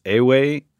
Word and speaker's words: Ewe: I found this tradition of West African drumming, and I will probably mess up the pronunciation, but I believe it's Ewe, E-W-E.